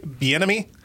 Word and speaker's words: Bieniemy: They're looking at Eric Bieniemy. Am I saying that right? – Yes.